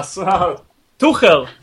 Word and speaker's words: Tuchel: So Tuchel.